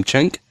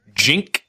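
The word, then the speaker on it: Cenk: Chink? Chink Uger? – Jink. It's the Jink. Only name or word that I know of that starts with a C but is pronounced Jink with a J.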